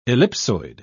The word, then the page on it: ellipsoid: British English